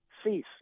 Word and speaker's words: Suess: It looks like Seuss, S-U-E-S-S, but it's pronounced Cease, like cease and desist.